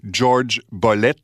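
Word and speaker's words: Jorge Bolet: un récital live du pianiste cubain Jorge Bollet, que je vais prononcer comme il le faisait lui-même, George Bollet, au Carnegie Hall de New York en février 1974.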